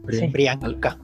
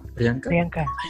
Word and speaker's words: Priyanka: Brianca. Priyanka.